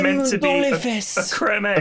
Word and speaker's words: Creme Egg: I just saw the Cadbury's Twitter had replied that cream egg is actually pronounced creme egg.